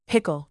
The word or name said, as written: pickle